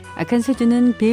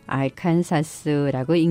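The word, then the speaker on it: Arkansas: 아칸소는 영어로 ARKANSAS라고 쓰는데요. 그러다 보니까 글자 그대로 알칸사스라고 읽는 사람도 덜어 나옵니다.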